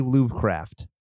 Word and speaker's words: Lovecraft: Lovecraft.